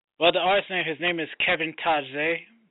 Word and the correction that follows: Tajay: Tajay. – Yeah, pronounced Tajay.